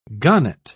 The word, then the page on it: gahnite: British English